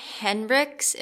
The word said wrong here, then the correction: Heinrichs: Am I saying that right? – Heinrichs. – Heinrichs. All right.